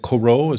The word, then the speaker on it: CoRoT: What is the one, is it Corot, as in the painter? How do you pronounce that? C-O-R-O-T.